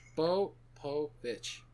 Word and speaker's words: Spopovich: Three syllables. That's three syllables. – Spopovich. – There you go.